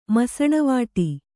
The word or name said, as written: ಮಸಣವಟ್ಟೆ